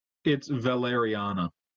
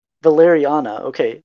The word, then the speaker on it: Valeriana: And then they come across Valerina? Am I saying that right? – It's Valeriana. – Valeriana, okay. So they come across Valeriana's hut, similar to the Bazaar Bazaar.